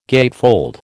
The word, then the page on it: gatefold: American English